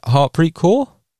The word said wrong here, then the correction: Harmanpreet Kaur: Harmanpreet Kaur. Harman.